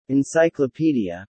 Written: encyclopedia

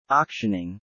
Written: auctioning

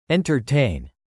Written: entertain

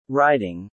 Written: riding

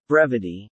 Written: brevity

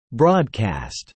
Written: broadcast

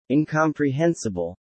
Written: incomprehensible

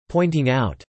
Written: pointing out

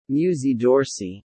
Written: Musée d'Orsay

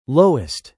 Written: lowest

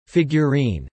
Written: figurine

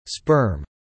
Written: sperm

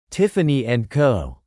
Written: Tiffany and Co

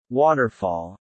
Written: waterfall

